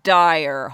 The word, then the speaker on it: Dyer: And boy, is it Dyer. Holy shit. Although her name is not spelled that way. It's spelled D-Y-E-R.